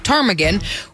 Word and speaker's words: ptarmigan: In fact, they had no clue how to even begin spelling the word ptarmigan, which to be fair, makes sense because there's a silent P at the front of that word, which is really stupid.